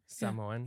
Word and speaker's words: Samoan: Samoan.